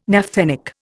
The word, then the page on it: naphthenic: Indian